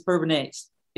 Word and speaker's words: Bourbonnais: And I said, no, Bourbonnais is Bourbonnais. It's not Bourbonnais.